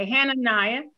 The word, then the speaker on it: Hananiah: is it Hananiah? How do you pronounce that? Hananiah? Okay, Hananiah, the prophet, hear now, Hananiah, the Lord has not sent thee, but thou makest this people to trust in a lie.